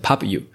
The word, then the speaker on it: Pubu: 好，他就说呢，这个 Pubu 其实是两个字的组合啦。第一个是 Pub，Pub 就是 publish 发表的意思，Pub。那后面那个 U 是指 yours，就是你的 Pub yours，所以要念成 Pubu。